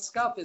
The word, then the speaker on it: Skup: Ted Skup is right.